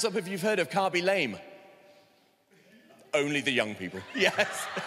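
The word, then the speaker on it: Khaby Lame: KB Lame. Carby. Okay, I said it wrong to start with, but that's fine because I'm not young, so that's all right. Okay, Carby Lame.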